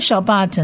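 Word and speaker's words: Shabbat (שבת): 这个 Shabbat 呢，其实跟英文的 Sabbath 这个字蛮像的。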